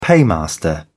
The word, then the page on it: paymaster: British English